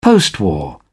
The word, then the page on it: postwar: British English